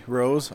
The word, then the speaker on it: Rose: My name is Jesse Rose, R-O-S-E.